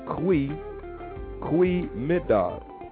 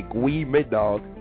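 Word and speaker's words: goedemiddag: So like your G, your G is actually a C, is actually a Q. It's also like Q. You don't say G. You don't look and say Gwee Mid Dog.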